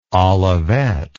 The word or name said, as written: olivette